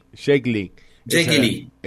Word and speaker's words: Jake Lee: que se llamaba Jack Lee, ¿no? Sería más o menos. – Sí, Jake Lee. – Esa era la pronunciación.